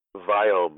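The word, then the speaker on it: Viome: As being an Indian, I cannot pronounce the word we. So when I say Wyom, you really mean biome. – Right. – So V as in Victor, I-O-M-E.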